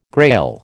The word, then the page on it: Grail: American English